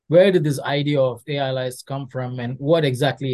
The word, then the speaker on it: AILYZE: I think it's AI-based analyze, right? So AILYZE.